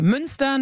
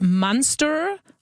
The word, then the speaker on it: Münster: Suddenly people started talking about Munster or Neuanlage instead of Neuanlage.